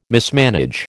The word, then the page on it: mismanage: American English